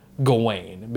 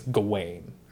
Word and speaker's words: Gawain: And I did a lot of digging into the pronunciation of his name, and I believe the actual pronunciation is Gawain, because it's W-A-I-N. – Yeah. – Just like my name, so I think it's Gawain.